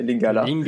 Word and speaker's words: Lingala: Alors après, les langues majoritaires, c'est le français, ainsi que le lingala. Je ne sais pas si je l'ai bien prononcé, Lingala. – Lingala.